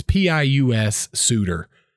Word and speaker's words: Pius Suter: I don't know if I'm saying his name right. Pius? It's Pius Suter.